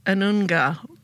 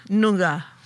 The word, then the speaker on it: Noongar: So Claire, and I'm going to pronounce this properly, is Anunga, is that right? – Noongar.